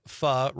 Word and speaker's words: pho: Eric, pho rhymes with duh, is a Vietnamese soup, includes rice noodles, shaved meat, other meats, and veggies can go into it.